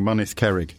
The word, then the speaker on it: Mynydd Cerrig: We'll begin with me almost certainly mispronouncing Mynydd Cerrig. – No, that's spot on. – Get in. – That is. You've pronounced that better than a lot of Welsh people would do, yeah.